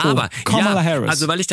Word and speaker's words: Kamala: Kamala. Es wird auf der ersten Silbe betont Und sie selbst sagt, es wird ausgesprochen wie das englische Wort für Komma, Komma und La.